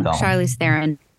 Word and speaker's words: Charlize Theron: Oh yeah, Charlize Theron was in it too. – I totally said her name wrong. – Charlize Theron. And now I just was looking it up.